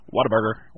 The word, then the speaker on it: Whataburger: It's pretty funny, but anyway, and then they had signs for, like, Whatabugger, Whataburger, Whataburger, mm-hmm. Whataburger, mm-hmm. and it's what a burger, you yes. know, it's Whataburger.